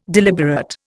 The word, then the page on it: deliberate: Indian